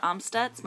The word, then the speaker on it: Amstutz: All of my social media handles are at Sandra Amstutz.